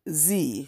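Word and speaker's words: Zi: But in response, the good Z says, very patiently, very self-controlled, trying to breathe, I smile and I say ever so quietly, "Yes, that is my real name. It's Z, but it's spelled Z-I."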